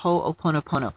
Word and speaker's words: Ho'oponopono: And I'll say maybe a little bit of what's called the Ho'oponopono. If you've never looked that up, it's spelled, I think it's spelled, it's like it's Hawaiian words, it's H-O, and then there's apostrophe O-P-O-N-O-P-O-N-O, Ho'oponopono.